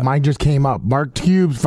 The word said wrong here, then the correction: Kubis: $5. – It's Kubis. – Shut up. – It's Kubis. Mark Kubis. – Say it correctly, Mark Kubis, $5 cash.